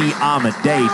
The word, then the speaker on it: Amedee: And I beg your pardon, it's Lee Amedee. D-E-E equates to D-A-Y in his pronunciation.